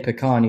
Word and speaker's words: pecan: You got to say pecan. You got to say it right.